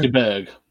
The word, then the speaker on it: DeBerg: Was it A, Steve Deberge? B, Doug Williams? DeBerg? – Do you mean Steve DeBerg? – DeBerg, yeah, sorry.